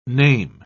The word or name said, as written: name